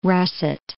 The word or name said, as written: russet